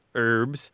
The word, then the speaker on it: herb: And in French, though, they don't pronounce the H, so the pronunciation was herb, right? That's the way the French do it.